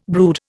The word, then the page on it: brood: Indian